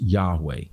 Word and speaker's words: Yahweh: or probably more accurately in Hebrew was pronounced Yahweh.